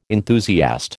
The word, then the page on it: enthusiast: American English